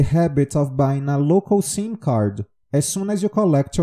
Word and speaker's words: SIM card: Com M, tá? Toda vez que a gente tem um M, é importante que a gente pronuncie a palavra com o M fechado no fim da palavra. Então, SIM card.